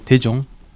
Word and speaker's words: Taejong: Bon, je m'appelle TJ, ça en coréen c'est Taejong, mais les Français ont difficulté de prononcer mon prénom, donc moi je décide de TJ, parce que c'est plus facile.